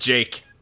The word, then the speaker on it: JK: He does say, yeah, it's spelled JK, but it's pronounced Jake.